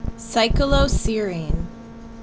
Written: CycloSERINE

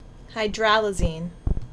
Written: HydrALAZINE

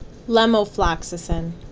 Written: Lomefloxacin

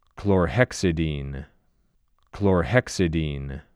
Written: Chlorhexidine

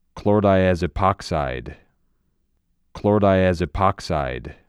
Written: Chlordiazepoxide

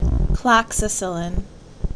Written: Cloxacillin

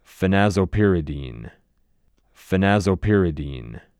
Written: Phenazopyridine

